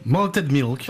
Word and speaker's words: Malted Milk: Oh, comment vous prononcez? – Malted Milk.